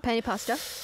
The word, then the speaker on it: penne: Is that how penny. You pronounce it? Penne pasta? – Penne pasta.